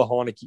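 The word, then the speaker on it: Heinicke: As we know, he is one of the quarterback Henschke's favorites. Heineke. How do you say his name, Rat? You know how to say it. – Yeah, we got Taylor Heineke, mate.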